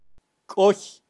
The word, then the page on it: koy: Kaqchikel